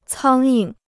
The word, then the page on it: 苍蝇: cāng ying